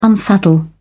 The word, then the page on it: unsaddle: British English